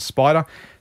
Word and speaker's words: SPDR: SPDR, you'll see. So they pronounce it Spider.